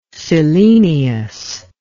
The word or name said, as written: selenious